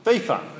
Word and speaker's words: FIFA: Can't even say it right, can I? FIFA, thank you. Yes, I did have it right before.